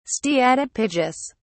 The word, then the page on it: steatopygous: British English